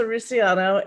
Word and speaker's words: Russiano: Melissa Russiano,